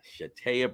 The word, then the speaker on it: Shatayia: Shatayia Baker.